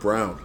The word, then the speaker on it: Braun: So it's actually pronounced Brown.